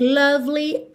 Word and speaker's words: lovely: Pretty woman, that you look lovely as can be. Então aqui, that you, ele juntou com rarara. That you look lovely. Lovely. Atenção como esse é do meio não é pronunciado.